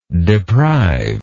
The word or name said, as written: deprived